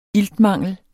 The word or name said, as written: iltmangel